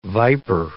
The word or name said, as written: viper